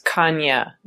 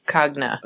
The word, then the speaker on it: Cagna: On a similar note, G's in Italian sound like Y's usually, so it's Cagna, not Cagna.